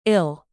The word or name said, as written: ill